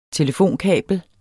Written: telefonkabel

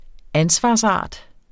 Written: ansvarsart

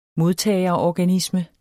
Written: modtagerorganisme

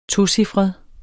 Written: tocifret

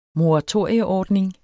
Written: moratorieordning

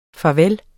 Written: farveller

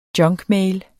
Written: junkmail

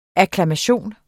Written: akklamation

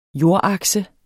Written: jordakse

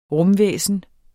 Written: rumvæsen

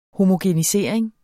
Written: homogenisering

